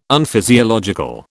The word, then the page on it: Unphysiological: British English